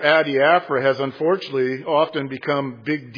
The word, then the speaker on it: adiaphora: I'm going to spell it for you. Then you'll pronounce it a whole lot better. A-D-I-A-P-H-O-R-A. A-D-I-A-P-H-O-R-A. Adiaphora. Say it. Ready? Adiaphora. Much better.